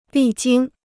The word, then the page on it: 历经: lì jīng